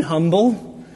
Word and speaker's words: humble: I paused for just a minute before asking her, "'Do you mean humble?' Admittedly, it was one of my less astute moments, and she fixed a withering glare on me. Before replying, I said, "Humble, the H is silent."